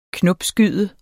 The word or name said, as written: knopskyde